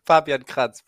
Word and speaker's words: Fabian Kratz: That sounds about right. Fabian Kratz, but that's like the German way.